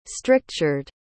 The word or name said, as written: strictured